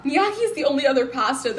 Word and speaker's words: gnocchi: Gnocchi? – Yes!